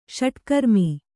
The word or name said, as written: ಷಟ್ಕರ್ಮಿ